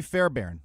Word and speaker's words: Fairbairn: It's Kylie Fairbairn.